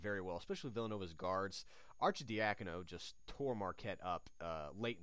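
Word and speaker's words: Arcidiacono: 70 points between Bell, Hilliard, and Archidiakono. – Well, first of all, congratulations on pronouncing his name correctly.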